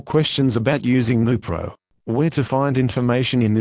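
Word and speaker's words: Neupro: This is the consumer medicine information for Nupro, pronounced Nupro.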